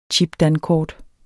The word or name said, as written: chipdankort